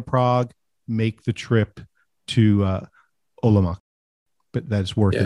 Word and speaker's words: Olomouc: Olomouc? – Yeah, it's Olomouc.